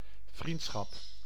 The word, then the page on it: vriendschap: /vrintsxɑp/